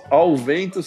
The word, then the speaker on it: Allvento: Ao Vento se escreve A-L-L-V-E-N-T-O.